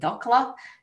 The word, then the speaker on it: dhokla: It's spelled D-H-O-K-L-A, Dokla.